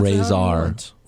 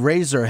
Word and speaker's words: Rezar: Is it Razor or Razor? – R-E-Z-A-R. Pronounce Razor. Razor? How did they say it? – I thought it was Razor.